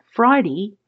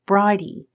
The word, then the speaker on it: Bridie: As the session continued, she corrected the pronunciation of her name, saying it was not Friday, but Bridie short for Bridget.